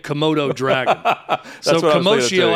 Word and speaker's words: commotio cordis: I've heard different pronunciations, by the way. I've heard two different doctors say commotio, like commotion, and then Dr. Montgomery, they said commotio cordis.